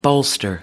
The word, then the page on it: bolster: American English